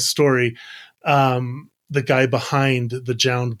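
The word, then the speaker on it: JJJJound: If that's how you're supposed to pronounce it's four J's. So it's pronounced. I did look into. – Is it just Jound? – It's just pronounced Jound, and it is. So it's there's four J's at the front of it, though. So yeah, it's Jound.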